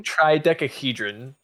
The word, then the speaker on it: Tridecahedron: The Tidecahedron blood summoning. – Say it right. Tidecahedron. – Tridecahedron blood summoning.